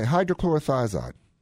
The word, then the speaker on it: hydrochlorothiazide: C-H-L-O-R-I-T-H, and then I kind of can't remember. But it's a diuretic. – Okay, hydrochlorothiazide.